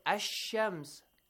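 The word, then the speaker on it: Ash-Shams (الشمس): For example, we say, Ash-Shams il Lam. – Yes. – Skip silent letter. Not always. Okay, silent. It's that Alif and Lam. The Lam is not always silent letter. But if it is followed by a Shamsi letter or Sun letter, it is always silent.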